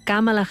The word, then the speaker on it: Kamala: De mare índia, pare jamaicà, va haver d'explicar moltes vegades que el nom es pronunciava Kamala, que l'Índia és un nom molt comú i que vol dir flor de lotus.